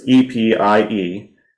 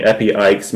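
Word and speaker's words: epieikes (ἐπιεικής): It's sort of a hard one here, so it's epieikes. So you have an IEI in there, so however you feel like that should be pronounced. So I'm saying epieikes, meaning mild, gentle, or patient.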